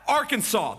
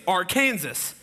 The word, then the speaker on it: Arkansas: Okay, how about Arkansas? That's how we pronounce it. It's illegal to mispronounce the state name because there are some people out there who think it's Arkansas.